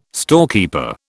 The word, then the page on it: storekeeper: British English